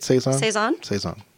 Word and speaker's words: Saison: Saison.